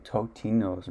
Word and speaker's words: Totino's: Dude, off-brand Tostino's, no, Totino's. Remember they had that ad where they kept telling people how to say it? – God damn it.